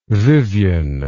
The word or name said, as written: vivyan